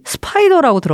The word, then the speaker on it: SPDR: 이게 바로 혹시 스파이더라고 들어봤어요? – 아니요. – 이게 그 인덱스 펀드 중에 이제 투자하면은 ETF 투자할 때 되게 많이 보는 건데 SPDR이라고. 근데 이걸 이제 스파이더라고 읽어가지고 이제 거미라고 많이 하거든요.